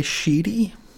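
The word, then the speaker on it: Schiti: Valerio S-C-H-I-T-I. I don't want to say it phonetically. Valerio will say Sheedy?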